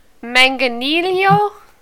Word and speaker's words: Manganiello: Joseph Mangaletto? Mangaletto? – There is no T in the last name. – Then you pronounce it. – I mean, Manganiello?